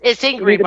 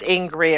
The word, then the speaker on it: Ingrid: It's Ingrid, by the way, but it's okay, Stephen. I just want you to be clear. It's Ingrid. It rhymes with angry, but the mnemonic is, if you're not an angry American, you're not paying attention. So it does rhyme with angry.